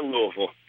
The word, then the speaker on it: Louisville: I'm calling from Louisville, Kentucky.